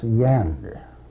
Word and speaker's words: yonder: The word yonder, meaning something at a distance, they pronounce yander.